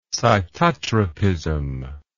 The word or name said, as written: cytotropism